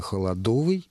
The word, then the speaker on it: холодовый: Да, в русском языке есть прилагательное «холодовый» именно с таким ударением на суффиксе.